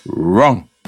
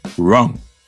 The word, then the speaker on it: wrong: 여기서 wrong, wrong 발음할 때 wrong이라고 하지 마시고 입을 둥글게 내밀어서 개소리를 내시면 돼요. wrong, wrong.